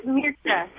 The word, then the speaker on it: Mircea: Mircea Eliade, I hope I'm pronouncing his name correctly. I don't often hear it said, but... – Yeah, Mircea, yeah.